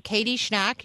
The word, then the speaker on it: Schnack: And then her last name is spelled S-C-H-N-A-C-K. So Katie Schnack.